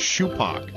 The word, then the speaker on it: Shupak: But he walked up and he goes, I think it's Shupak. Here comes the 1-2. And that's low, and it's two balls and two strikes. And it comes to be that the correct pronunciation was Shupak.